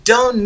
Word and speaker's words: don't: Fijaos cómo pronuncio you don't need. You don't need.